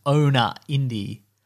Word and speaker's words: ownaindi: So I think the website is supposed to be pronounced Owner Indie. Like, okay. You can own a indie.